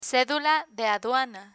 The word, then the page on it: cedula de aduana: spanish